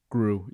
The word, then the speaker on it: Gru: Gru, yeah.